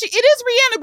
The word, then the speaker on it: Rihanna: You know, as you know, I believe that she kind of went away because we never pronounced her name correctly one time. She was like, well, I'm done. It's Rihanna. – It is Rihanna, but she says she don't care if you call her Rihanna.